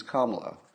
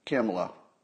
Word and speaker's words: Kamala: She's not Kamala, she's Kamala. We must all learn to pronounce it properly. It's not Kamala, it's not Camelot. It's not Camelot, it's not Kamala.